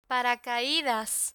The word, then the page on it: paracaidas: Spanish